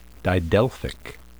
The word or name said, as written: didelphic